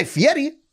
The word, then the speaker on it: Fieri: I'm Guy Fieri. That's how you pronounce his name. Do you know that? – Yeah, Fieri. – Fieri. He makes sure to do it.